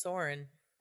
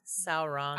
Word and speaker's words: Sauron: Who's Soren? – Oh, who's the bad guy in the tower? I gotta rewatch the movies, obviously. – Sauron.